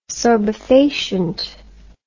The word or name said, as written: sorbefacient